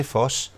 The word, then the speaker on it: Voss: Og det eneste, der egentlig forbinder ham med det tyske, det er, at hans øh, efternavn staves med, med V, der udtales som F, altså farve. Han hedder Tage Foss.